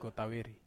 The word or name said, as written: kotaveri